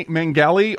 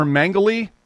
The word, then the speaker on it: Mengele: But what's interesting to note, folks, is when they were actually found out concerning the heinous crimes that they were committing, namely Mengele or Mengele, it's pronounced different ways in different countries, but he was called the Angel of Death.